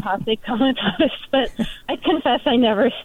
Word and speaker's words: Posse Comitatus: Now, I know that you have concepts and you have background in an idea called Posse, and I don't know if I'm saying it correctly, Comitatus? – That's right.